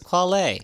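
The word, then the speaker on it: Quelle: It's spelled Quell. – Quell Chris. It's Quale.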